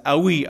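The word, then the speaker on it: awi (οὐαί): In Greek, "woe," it's pronounced like "ausi, ausi, ausi." This is the voice an eagle makes when he flies. When he flies over the heavens. Sorry, there's no S. It's awi, awi, awi. That's how the word is pronounced in Greek.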